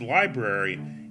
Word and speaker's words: LBRY: You could also earn cryptocurrency for creating content on specific platforms like Steemit, DTube, LBRY, or as it's often pronounced, Library, and more.